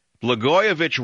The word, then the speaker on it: Blagojevich: Blagojevich rhymes with itch.